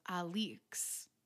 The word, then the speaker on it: Alix: So one thing that we heard from our friend Levi, who is listening to the audiobook, is that we've been saying Alex's name wrong. – Yeah. Levi informed us that the audiobook version says her name, Alix.